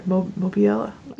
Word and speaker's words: Mobile: and the traditional Azalea Trail Maids from Mobile, Alabama. I'm sure I said that wrong, sorry. Mobile? – No, you had it right. Yeah, okay. Mobile, yep.